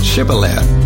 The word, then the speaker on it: Shibboleth: Anyone who tried to cross had to say the word Shibboleth. Those from Ephraim could not pronounce it correctly, saying a s sound rather than a sh sound, and thereby were identified.